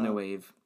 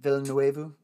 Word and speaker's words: Villeneuve: Uh, Villeneuve. – Uh, Villeneuve.